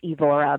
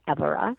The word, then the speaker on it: Évora: And the main towns there are, well, Évora, which most people pronounce Evora, but it's pronounced Évora.